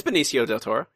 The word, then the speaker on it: Benicio del Toro: It's Benicio del Toro.